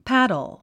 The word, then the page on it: paddle: pǽdl; パドゥ